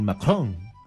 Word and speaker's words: Macron: Emmanuel Macron. Así se pronuncia, Ruth, hemos practicado.